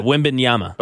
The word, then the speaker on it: Wembanyama: Victor Wembanyama. – Is that how you pronounce it? Yeah, Wembanyama.